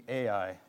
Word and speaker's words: Ai: By the way, I'll pronounce the city Ai, just to mix it up a little. Last week, Mitch said I. This week, I'll say Ai, and you can decide for yourself how it's supposed to be pronounced.